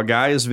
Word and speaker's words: Gaia: How did you pronounce it? Gaia? – Gaia, yes. – Okay, well, Gaia's video collections also include yoga videos, which is Satanist.